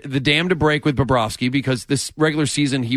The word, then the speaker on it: Bobrovsky: Bobrovsky with a V. – Bobrovsky.